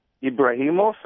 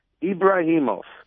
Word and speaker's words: Ebrahimoff: So if you Google my name, Reuben Ebrahimoff, and I'll spell it, you can find me as well. So the last name is spelled Ebrahimoff, as in Frank, Ebrahimoff.